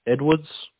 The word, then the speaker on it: Edwards: Yep, L-A-H. – Yep. – T-R-E. – Yep. – L-L. – Yeah, and last name? – Edwards, E-D-W-A-R-D-S.